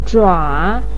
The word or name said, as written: zhua3